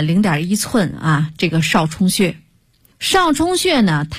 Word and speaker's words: 少冲: 第三个是最后末端的穴位少冲穴，这个冲啊是两点水加一个中国的冲，冲锋陷阵的冲啊，少呢就是多音字啊，多少的少。